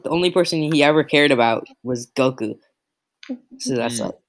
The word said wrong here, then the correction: Grogu: I'm sorry, did you say Goku? – Is that not how it's pronounced? – It's Grogu, not Goku. – G-R-O-G-U.